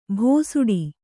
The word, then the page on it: ಭೋಸುಡಿ: bhōsuḍi